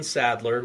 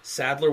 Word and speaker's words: Sadler: So if you just go at Christian Sadler, which is spelled C-H-R-I-S-T-I-O-N, Sadler with one D, S-A-D-L-E-R.